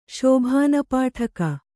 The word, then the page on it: ಶೋಭಾನಪಾಠಕ: Kannada